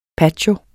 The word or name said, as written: patio